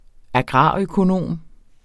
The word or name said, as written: agrarøkonom